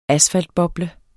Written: asfaltboble

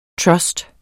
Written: trust

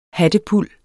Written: hattepuld

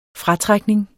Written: fratrækning

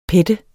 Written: pette

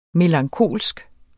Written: melankolsk

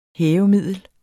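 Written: hævemiddel

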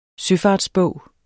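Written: SØFARTSBOG

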